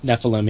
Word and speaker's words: Nephilim: It's a spinoff from his Nephilim book. Did I pronounce that right? – Nephilim, yeah.